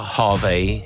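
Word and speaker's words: HRVY: però in realtà si pronuncia Harvey, esattamente con la classica pronuncia inglese.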